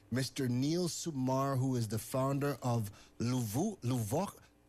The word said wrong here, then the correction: Levuca: I'm always going to butcher this. Levuca. Neil, just give me. Okay, good. I think I nailed it.